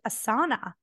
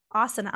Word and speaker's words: asana: but on their on their website they're like we pronounce it asana yeah. – Or you could pronounce it the other way, the other Sanskrit way, asana. I'm like, there's no other Sanskrit way. That is the way to pronounce it.